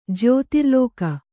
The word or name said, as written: ಜ್ಯೋತಿರ್ಲೋಕ